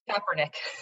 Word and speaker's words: Kaepernick: and I'm going to butcher his name because my husband tells me I say it wrong every time because I'm not a huge sports fan, but uh, Colin, you say Kaepernick.